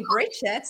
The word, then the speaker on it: Brigitte: I'm Brigitte Bojkowszky, and everyone calls me Brigitte. So that is a very international kind of pronouncing my name. So I go with that.